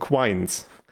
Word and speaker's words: quine: Und ja, hier wird es jetzt ein bisschen repetitiv, weil es geht jetzt um Quines. Also geschrieben Q-U-I-N-E und dann halt englisch ausgesprochen Quine.